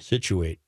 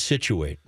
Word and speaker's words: Scituate: Spell it. – S-C-I-T. – Yep. – U-A-T-E. – Situate? I believe so, but okay.